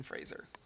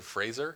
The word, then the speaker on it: Fraser: Starring Brendan Fraser.